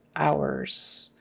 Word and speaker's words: hours: O-U-R is hour, like O-W-E-R, kind of, hour. And the H is silent. Don't forget, no H. Don't say visiting hours, visiting hours. And that final S again is pronounced as a Z.